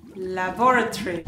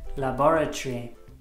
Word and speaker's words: laboratory: Cuidado con la pronunciación. Laboratory. – Laboratory.